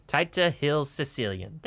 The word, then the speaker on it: Taita Hills Caecilian: Taita Hills Caecilian.